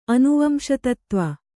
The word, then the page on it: ಅನುವಂಶತತ್ತ್ವ: anuvamśatattva